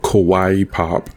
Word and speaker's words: kawaii: Now with Bastet, her skin that I love to play with her is the, uh, it's called Kawaii Pop it's like the word Hawaii, but spelled with a K because it's like K-pop Hawaii, so it's kawaii pop.